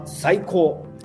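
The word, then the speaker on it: saiko (最高): It's called saiko. – Psycho. – It sounds like psycho.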